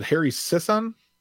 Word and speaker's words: Harry Sisson: And it sounds like this, you said Harry Sisson? – I believe that's how you say his name, yes.